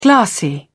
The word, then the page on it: glassy: British English